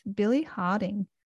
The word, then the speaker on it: Billy Harding: I'm your host, Felicia Jaramus, and today I have the pleasure of speaking with Billy Harding. That's how you pronounce it, is it? Billy Harding? – That's it, yeah, Billy Harding, yeah.